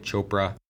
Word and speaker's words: Chopra: President Biden nominated a gentleman named Rohit Chopra, rhymes with Oprah, to be the head of the CFPB.